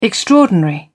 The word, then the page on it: extraordinary: British English